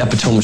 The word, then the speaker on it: epitome: He's the epitome of strength. He's a wolf. – Epitome. – Obviously, he means the epitome. It's not pronounced epitome.